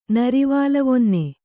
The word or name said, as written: ನರಿವಾಲವೊನ್ನೆ